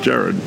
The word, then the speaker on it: Gerard: And it's G for golf. – Yes. – E for echo. R for Romeo. A for alpha. R for Romeo. D for delta. And it's pronounced Gerard.